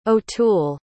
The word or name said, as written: O'Toole